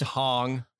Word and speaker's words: Hong: There's always James Hung is in some form or another in one movie. Just jumps in there. Mm-hmm. He's in everything. God, you're actually using correct pronunciation. I'm just James Hong.